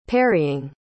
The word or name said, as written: parrying